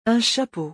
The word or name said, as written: Un chapeau